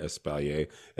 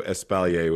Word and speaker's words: espalier: Espalier, espalier, espalier. I've heard them all. They're all fine. It is a French term, so espalier was probably correct.